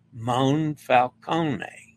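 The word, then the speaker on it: Monfalcone: A reminder to pronounce the O as oh no, and then open A as ah, as in father. Moonfalcone.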